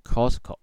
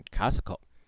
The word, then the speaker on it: Costco: 首先跟大家介绍的是 Costco 好市多，注意啊，这个 O 的发音是啊，而不是 O 哦，所以不要再念成 Costco，要念成 Costco。很多人应该以为台湾第一间好市多是内湖店哦，啊，各位其实不是啊，台湾第一间的 Costco 呢，竟然是高雄店啊，就是梦时代旁边那一间哦，那是在一九九七年的时候呢来做开幕的，到现在已经有二十六年了。